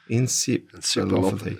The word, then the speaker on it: encephalopathy: Encephalopathy. Yeah. – How do I, you, can you say it better than me? Encephalopathy.